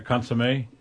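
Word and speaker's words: consommé: Did I say it right? Consomme? – Yes.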